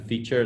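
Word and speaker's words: Phiture: It's we pronounce it Feature. It's with a P-H, P-H-I-T-U-R-E.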